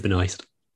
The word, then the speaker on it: Benoist: Melissa Benoist.